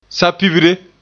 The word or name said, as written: sapibre